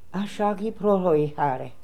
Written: Ashagiprotoehary

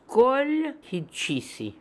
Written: Kolhidshissi

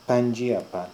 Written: Piandjiapan

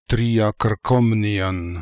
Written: Triakrkomnian

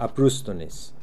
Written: Abrustdonis

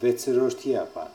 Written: Vetserordiapan

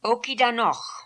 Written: Okidanokh

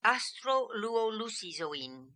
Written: Astroluolucizoin

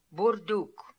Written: Boordook

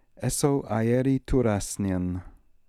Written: Essoaieritoorassnian

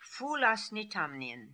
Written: Foolasnitamnian

